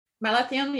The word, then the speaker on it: Malathion: And so in this instance, the Malathion, is that how would you say it? – Malathion. Yeah, that's what I would say.